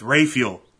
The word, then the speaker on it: Rafael: D-A-N-R-A-F-A-E-L. – Don Ralph. – R-A-F-A-E-L. Raphael. – Don Ralph. – No. It's Raphael. Dan Raphael. – How do you spell Raphael? – R-A-F-I-E-L? – That's Raphael. – R-A-P-H-A-E-L.